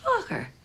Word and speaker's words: Focker: Oh, just like it's spelled. F-O-C-K-E-R. – Fokker. – Hmm. Fokker.